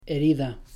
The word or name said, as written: herida